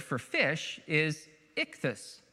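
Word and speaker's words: ichthus (ἰχθύς): It's pronounced, this is ichthus, ichthus.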